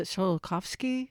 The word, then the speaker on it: Tsiolkovsky: Another Russian who contributed greatly to the development of rocket science, I'm going to probably butcher his name, is it Tsiolkovsky? – Yeah. – Oh, I said it right?